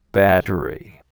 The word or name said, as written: battery